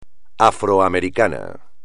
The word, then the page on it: afroamericano: espagnol